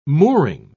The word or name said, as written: mooring